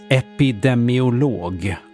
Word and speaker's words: epidemiolog: Ordet uttalas epidemiolog och stavas även så, oavsett vad Lina påstår.